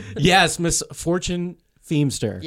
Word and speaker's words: Feimster: That is the right way to say it. – Oh. – Yeah, because how did you say it? – I didn't know. I said it was either Feimster or Feimster. – It looks like Feimster, but it's Feimster.